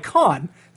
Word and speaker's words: Kaan: Guys, Don Cheadle's character in House of Lies is named Khan, K-A-A-N, which is pronounced like Khan, C-O-N.